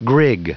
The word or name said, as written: grig